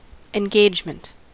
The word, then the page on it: engagement: American English